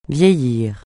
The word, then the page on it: vieillir: francais